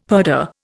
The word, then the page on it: purdah: Indian